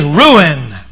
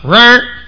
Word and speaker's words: ruin: The second word is ruin. Ruin, R-U-I-N.